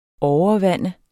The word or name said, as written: overvande